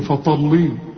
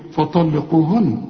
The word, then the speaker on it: فطلقوهن: شوف فطلي، اوعى تقول لي فطلي، فطلقوهن، ما انا بقول لك خد بالك. ليه؟ برضو الطاء فيها والقاف مفخمين دول.